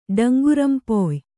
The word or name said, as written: ಡಂಗುರಂಪೊಯ್